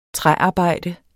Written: træarbejde

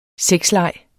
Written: sexleg

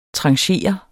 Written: tranchere

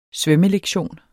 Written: svømmelektion

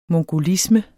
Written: mongolisme

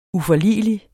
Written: uforligelig

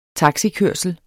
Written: taxikørsel

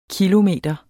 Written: kilometer

